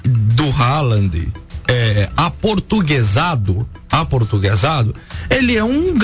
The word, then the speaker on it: Haaland: É Haaland, em português é Haaland.